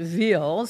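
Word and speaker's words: Wil: So he goes, "Oh, you know, there's a marathon in Will, W-I-L-L, they pronounce it Ville, Switzerland, and you should run in it."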